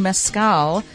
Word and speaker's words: mezcal: And we are talking mezcal. Am I saying it right with Lou Bank? – Yes. Mezcal? That's good enough.